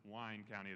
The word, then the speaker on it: Wayne County: Yes, it's Wayne, but it's pronounced Wine County there.